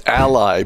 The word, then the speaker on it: Ally: I believe it's Ally because their television commercials, I think, pronounce it that way.